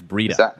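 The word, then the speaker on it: Breida: It's Breda. It's Matt Breda. Breda like cheetah.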